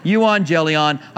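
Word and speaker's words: Euangelion (εὐαγγέλιον): It breaks it up into two parts. Euon, angelion, my toast. Euon, angelion, my toast. And then I drop off the toast. Euangelion.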